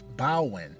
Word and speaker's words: Baron: And the way he's really pronounced his name, it wouldn't be Baron, as it's spelled like B-A-R-O-N. We can say it that way, of course, and that wouldn't be a wrong way of saying it or pronouncing it. But his name also can be pronounced Bawen with a W, B-A-W, Bawen Samedi.